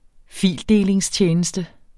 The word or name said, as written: fildelingstjeneste